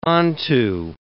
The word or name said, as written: onto